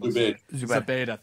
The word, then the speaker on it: Zubaydah: I can't pronounce his name. – Zubaydah, thank you.